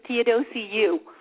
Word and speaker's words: Theodosiou: My last name is almost, I don't think it's been pronounced correctly in like 5 years. It's actually Theodosiou.